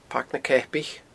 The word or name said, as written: Pairc na Ceapaich